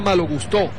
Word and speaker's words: Malo Gusto: Estaba esta mañana escuchando la previa que hacía el diario francés L'Equipe, una previa en video, y llaman a malo gusto, malo gustó, pronuncian malo gustó, ¿en serio? – Así, como a no es malo gusto, sino malo gustó.